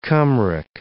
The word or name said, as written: Cymric